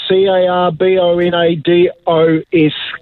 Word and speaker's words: Carbonados: I think I think I'm trying to pronounce it right. Carbonadas. It is C A R B O N A D O S, Carbonados.